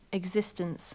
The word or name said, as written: existence